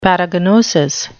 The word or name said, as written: paragnosis